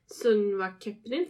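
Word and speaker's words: Söngvakeppnin: Eh, på Island så har de ju deras Melodifestivalen, uttal? – Sunva Kepnin. – Ja, mycket bra.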